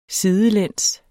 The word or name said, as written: sidelæns